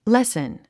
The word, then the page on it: lessen: lésn; レスン